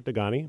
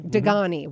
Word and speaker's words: Dugoni: Gentleman Robert Dugoni, am I pronouncing that correctly? – Yeah, Robert Dugoni. – Dugoni, Wall Street Journal and New York Times bestselling author of My Sister's Grave.